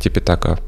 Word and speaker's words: Типитака: Основной текст называется Типитака или Трипитака, э, в зависимости от того, на каком языке читается. Типитака – палийский язык, язык пали, Трипитака – санскрит.